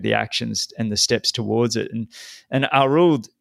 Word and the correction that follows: Arild: Is that how I pronounce his name? Arild? – Arild, yeah.